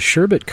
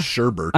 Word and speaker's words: sherbet: I like how you said sherbet. – Sherbet. – That's the correct way to say it, not sherbert. – I know, there's no R there.